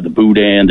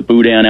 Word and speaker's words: boudin: The boudin out here is absolutely fantastic. – Well, and by the way, boudin is a silent N.